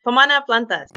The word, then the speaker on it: Pomana Plantas: First of all, from Berkeley, California on Solano Avenue, who is the owner of Pomana Plants. Am I saying that right? – Pomana Plantas. – Pomana Plantas. Yeah.